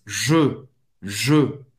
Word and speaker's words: Je: É diferente do, do pronome eu em francês. Je, je. Você vai pronunciar E fechado, E. Faz a boca do O e fala E, E. Je, je.